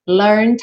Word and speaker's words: learned: Learned how. Percebe que o E não é pronunciado. Você não diz learned, tá? Então, ó, learned.